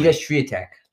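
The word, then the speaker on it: Iga Świątek: Iga Swiatek.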